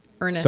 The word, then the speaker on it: Ernest: Yeah, I think it's James Ernest, but yeah, it's. I am very curious to see Ernst. – I just, you know, I'm letting you enjoy correcting my pronunciation. – Can I have my moment? – Yeah. – To say something correctly? – Ernest. Soak in it.